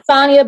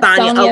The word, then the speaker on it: Sonja: It's Sonja, but I accept Sonja okay. Because people's accents are different.